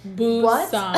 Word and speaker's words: Busan: Busan. Busan. B-U-S-A-N. I'm pretty sure we're saying it right because Lindsay and I did Google Translate to make sure we were saying the word right, so I'm pretty sure we are.